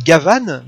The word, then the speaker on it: Gaban: Et il s'appelle Gavan, mais en fait ça s'écrit Gaban, comme Gaban, parce que c'est le flic de l'espace, et donc ils l'ont appelé Gaban en référence à Jean Gabin.